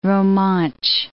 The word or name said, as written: Romansch